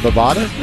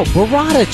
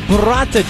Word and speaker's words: burrata: Is it Bovada? – No, burrata cheese. – It's Bovada. – No, it's burrata cheese.